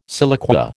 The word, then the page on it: siliqua: American English